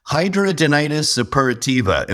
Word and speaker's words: hidradenitis suppurativa: Hidradenitis suppurativa. Am I close? Did I say it right? – Yeah, for sure. – Close enough, right? – Close enough.